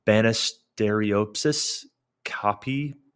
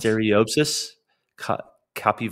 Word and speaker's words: Banisteriopsis caapi: So its composition is that it's made up, I don't know if I'm going to pronounce this rightly, but from the Banisteriopsis caapi vine. Is that, did I say that right, Josh? – Close enough. – Banisteriopsis caapi vine, and usually contains leaves from plants that are sources for psychoactive compound DMT, typically psychotropic verdes.